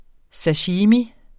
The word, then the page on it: sashimi: [ saˈɕiːmi ]; [ ˈsaɕimi ]